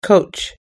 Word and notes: coach: us-english

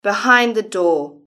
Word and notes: behind the door: British English

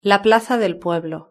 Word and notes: la plaza del pueblo: European Spanish

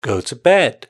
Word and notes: go to bed: British English

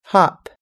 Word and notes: hop: us-english

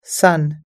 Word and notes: sun: us-english

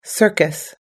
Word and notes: circus: us-english

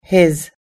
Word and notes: his: us-english

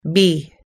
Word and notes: bee: us-english

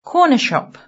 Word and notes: corner shop: British English

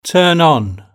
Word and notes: turn on: British English

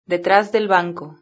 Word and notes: detras del banco: European Spanish

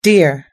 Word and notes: deer: us-english

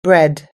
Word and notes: bread: American English

